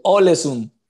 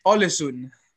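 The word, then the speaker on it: Ålesund: Eh, discúlpame si no pronuncio bien el nombre, el Elsund de Noruega. El Olesund. Olesund, dale, perfecto.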